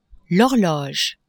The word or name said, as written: horloge